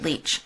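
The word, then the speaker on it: Lich: Leach. She pronounces it Leach.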